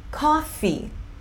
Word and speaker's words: coffee: E coffee, coffee. Duas sílabas, tá? Duas sílabas.